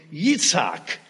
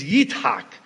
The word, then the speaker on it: Yitzhak: And so in Hebrew, Isaac, we pronounce it in English, is pronounced in Hebrew, Yitzhak.